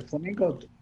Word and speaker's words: flamenco: So as a 15-year-old, he's not on the road yet, but he is a flamenco dancer. And I always say it wrong. I say it like the bird, and I get yelled at. Flamenco.